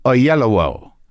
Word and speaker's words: Oyelowo: I just watched him pronounce it on a bit of video to make sure I knew how to pronounce it. Oyelowo.